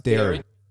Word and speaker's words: Derry: It's Derry. It's pronounced Derry.